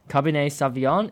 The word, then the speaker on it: Cabernet Sauvignon: Cabernet Sauvignon and Syrah.